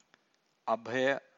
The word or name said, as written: abhaya